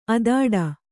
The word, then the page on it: ಅಧಟು: kannada